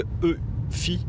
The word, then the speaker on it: eufy: Donc c'est la marque EFI, donc ça s'écrit E-U-F-Y qui faisait, je crois, jusqu'à présent euh, principalement des aspirateurs robots et des petites caméras euh, de surveillance du domicile.